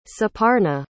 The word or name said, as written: suparna